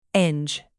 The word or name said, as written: binge